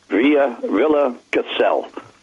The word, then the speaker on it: Villa Gesell: Gesell, G-S-E-L-L, Villa Gesell. Only in Argentina it's pronounced Villa, not Villa, Villa. But the town is Gesell, G-S-E-L-L-E.